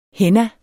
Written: henna

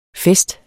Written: fest

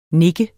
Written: nikke

